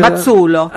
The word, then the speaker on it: Mazzuolo: ¿Mazzuolo se pronuncia? – Mazzuolo. – Ah, Mazzuolo. – Porque Doppia Z como la pizza.